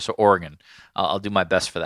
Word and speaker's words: Oregon: I thought it was Oregon, like Oregon Trail.